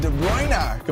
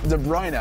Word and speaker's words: De Bruyne: Kevin De Bruyne completed a hat trick in the 24th minute in a crucial match against Wolves. I was told that's how you pronounce that sometimes.